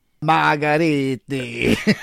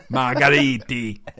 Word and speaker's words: Margheriti: Margheriti. – Margheriti.